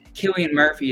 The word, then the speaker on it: Cillian Murphy: And then, is it, I think it's Cillian Murphy is how you say his name.